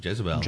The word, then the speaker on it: Jezebel: Today we have a tale that we'll entitle My God Can Kick Your God's Ass, and a related tale called Jezebel. Is that how you pronounce that name? – Jezebel.